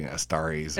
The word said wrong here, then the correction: Antares: Antares? – Antares, yeah. Antares.